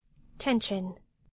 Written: tension

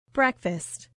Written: breakfast

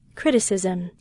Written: criticism

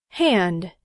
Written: hand

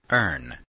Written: earn

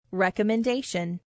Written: recommendation

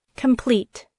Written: complete

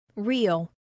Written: real

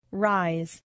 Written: rise

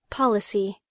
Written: policy

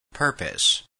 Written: purpose

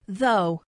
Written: though

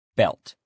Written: belt

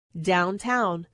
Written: downtown

